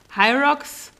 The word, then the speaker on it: Hyrox: Und zwar machen wir eine kleine Hyrox Woman Pro Hamburg.